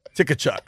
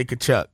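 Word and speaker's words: Tkachuk: Silent T, dog. – Yeah, it's probably like Slavic. I don't know the country of origin, as the spelling bee kids would say. – They just thought, yeah, we're going to put a silent T right here, and it's just going to say Kachuk.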